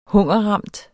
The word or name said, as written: hungerramt